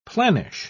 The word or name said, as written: planish